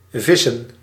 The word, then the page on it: pêche: /pɛʃ/